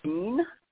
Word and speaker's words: Sigyn: I believe it's pronounced Sign, but it's spelled S-I-G-Y-N.